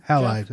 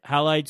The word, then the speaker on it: Halide: How do you pronounce that one? – Halide? Is what I've heard. – Halide, so I can take raw photos right off my iPhone.